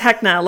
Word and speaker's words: Hecna: It's Hecna, like Vecna from D&D. Hecna.